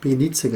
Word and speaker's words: Belize: Einfach Belize geschrieben, ausgesprochen Belize.